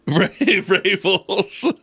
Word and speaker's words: Vrabels: And if he cared about the city of Cleveland, he'd say one name, and it rhymes with Schwabels. – Vrabels.